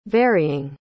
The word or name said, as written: varying